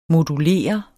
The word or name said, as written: modulere